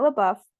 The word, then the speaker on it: LaBeouf: a lot happened, but to sum it up, Olivia said that Harry replaced Shia LaBeouf.